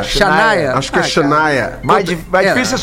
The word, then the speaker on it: Shania: Se apresentaram Aretha Franklin, Mariah Carey, Celine Dion, Gloria Stefan e a Shana Twain. – Adoro a Shania, Shania Twain. A, é a, é difícil esse nome.